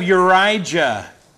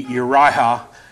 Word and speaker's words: Urijah: If we were going back to the original pronunciation, it'd be Uriah or Uriah. But in English, it's Urijah, because there is another Uriah, so we don't want to confuse these things.